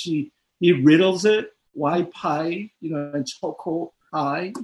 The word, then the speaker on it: phi: So why pi you know it's phi you know the f sound doesn't exist in korea right so it becomes a p sound